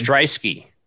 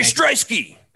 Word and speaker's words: Stryski: So pretty much they created the Tap, which is a 2 to 3 minute version, kind of like the SportsCenter on Snapchat with Gary Starewski. I think that's how you say his name. – Stryski. Gary Stryski.